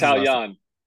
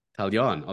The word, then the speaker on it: Taillon: I've always wondered, how do you pronounce Talion? – Talion.